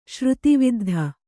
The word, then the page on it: ಶ್ರುತಿವಿದ್ಧ: Kannada